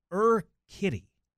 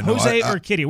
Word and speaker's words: Urquidy: It's pronounced Urquidy. – Yeah, Jose Urquidy. We were both way off.